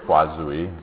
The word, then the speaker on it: Poiseuille: You're always wondering how to say it, right? Poiseuille. It's not too difficult. I didn't even say it with a French accent, but it sort of sounded French anyway, I'm sure.